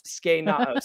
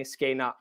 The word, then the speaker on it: Skenao: Skenao. Say it with me. Skenao. – Oh, you know I could not say that when I read the audiobook. – Skenao. – Brayden had to text me like phonetically, like say it like this. Skenao. Yeah. – So not skenao.